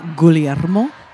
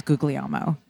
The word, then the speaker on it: Guglielmo: Okay, well, so the second G is actually silent. So it's really pronounced Guglielmo.